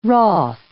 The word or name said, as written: wroth